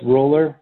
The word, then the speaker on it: Roller: I am Chris Roller. So I use Mike Shanks' Zoom notification. – Chris Roller? – So it's Chris, yeah. Chris Roller. The last name is spelled like roller skate. R-O-L-L-E-R.